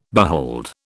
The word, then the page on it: behold: British English